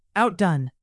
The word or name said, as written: outdone